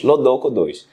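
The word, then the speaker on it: LoDoCo2: A pronúncia tem gente que fala de outro jeito, mas Lodolco 2.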